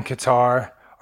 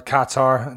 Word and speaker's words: Qatar: Okay, so that was Ardell Lawson over in Qatar, or Qatar, depending on how you want to pronounce it.